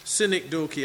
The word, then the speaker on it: synecdoche: This is a synecdoche. I'm going to get that word right. And it's spelled like synecdoche. S-Y-N-E-C-D-O-C-H-E.